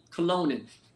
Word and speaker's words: Colonin: It's pronounced like Cologne and then N. Colonin.